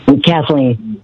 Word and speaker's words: Kathleen: Kathleen.